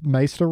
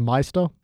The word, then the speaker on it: Maester: Although she does pronounce Maester wrong. She says Meister.